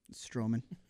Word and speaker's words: Strowman: Strowman.